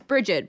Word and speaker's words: Brigitte: It's justified for me asking because it's Bridget, but it's spelled Brigitte, blah, blah, blah. – Yeah. I feel the same. I'm G-E-O-F-F instead of J-E-F-F.